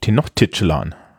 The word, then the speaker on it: Tenochtitlan: Ah, du hattest recht. Und ich hatte, ich hatte falsch. Tenochtitschlan. Tatsächlich. Das L ist ein bisschen komisch.